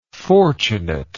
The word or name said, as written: fortunateness